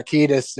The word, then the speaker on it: Kiedis: And then, like, didn't know it was Anthony Kiedis. That's how you pronounce it, right? – Yeah, and I think so. Yeah, Kiedis.